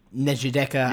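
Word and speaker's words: Njideka: Nejideka, yes. Akunili, Crosby, yeah, I think I pronounced it right. – Yeah, no, I think you did.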